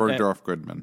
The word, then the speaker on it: Bergdorf Goodman: Bergdorf Goodman.